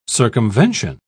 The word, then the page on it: circumvention: British English